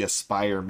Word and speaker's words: Aspyr: and with Lucasfilm Games handing KOTOR over to Asper is what I keep pronouncing it as, but it's actually Aspyr Media.